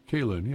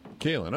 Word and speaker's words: Kaylin: Kaylin. It's just a unique spelling for this name. Kaylin. Is that how you say it, Dad? – Kaylin, yeah. – Kaylin, okay, yes.